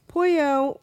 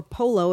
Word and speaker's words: Pollo: Del Real Foods, Pollo or Polo if you're pronouncing things poorly.